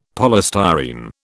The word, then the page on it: polystyrene: British English